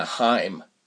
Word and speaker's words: Haim: The other lead being Alana Haim.